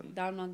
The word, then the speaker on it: Domhnall: Domhnall Gleeson. Say it so weird. Isn't he British? Shouldn't you be able to say that really easily? – He's from like... – Alexa, where is Domhnall Gleeson from?